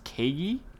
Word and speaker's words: Kagi: I believe it's pronounced Kagi, K-A-G-I.